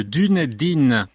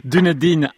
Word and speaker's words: Dunedin: Plus sur cette histoire passionnante dans Vera Moore, pianiste de Dunedin. J'espère que j'ai bien prononcé. – C'est bon. – Dunedin à Jouy en Josas par Christophe Baya, un livre disponible chez l'Harmattan.